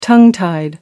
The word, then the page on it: tongue-tied: American English